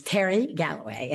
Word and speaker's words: Terry Galloway: First of all, my name is Terry Galloway, and it's spelled with a Y, not an I, because I've always thought an eye would make me a poodle.